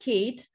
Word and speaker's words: Kate: It's pronounced Kate. My original name is Ekaterina, but Kate is like way easier, so everyone calls me Kate.